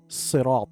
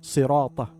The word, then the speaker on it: صراط: Correction, ensure correct pronunciation of Sirat, Sirat, as Sad should be pronounced with a heavy sound.